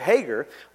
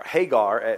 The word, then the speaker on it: Hagar: So his wife, Sarah, actually says, well, there's this young pretty chick named Hagar, or Hagar, that's how a lot of people pronounce it, but if you're from the South, it's Hagar.